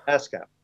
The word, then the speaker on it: ASCAP: How, how, would you pronounce that? – ASCAP. – ASCAP.